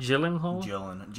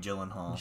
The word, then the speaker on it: Gyllenhaal: How do you say it? Gyllenhaal? Or Gyllenhaal. – Gyllenhaal. – Jake Gyllenhaal. – Gyllenhaal.